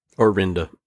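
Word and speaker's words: Arihnda: The they they also give you backstory to uh, uh how do you pronounce her first name? or Arinda, Arienda. – Orinda. – Orinda.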